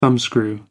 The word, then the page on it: thumbscrew: American English